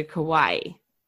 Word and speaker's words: Kawaii: It's Hawaii, but with a K is how I tell people to pronounce it. Yes. That is what I saw when I looked it up. Hawaii, but with a K. So Kawaii.